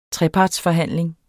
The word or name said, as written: TREPARTSFORHANDLING